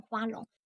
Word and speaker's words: 花容: 花容婀娜，天上无愁。这个花容，这个华容要念成花容。